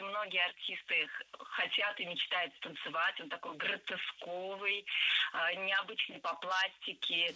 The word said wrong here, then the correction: гротесковый: Гротесковый, неверно. Правильный вариант – гротесковый. Кстати, если мы хотим образовать от существительного «гротеск» прилагательное, то предпочтительным вариантом будет слово «гротескный» с однозначным ударением на втором слоге. Вариант «гротесковый» тоже допустим, но ударение на втором слоге сохраняется.